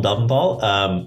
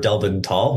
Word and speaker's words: Delventhal: My name is, Willem Delventhal, or Delventhal. We were just joking about how there's a fight in the family about which is the correct pronunciation.